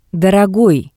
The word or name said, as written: Dorogoi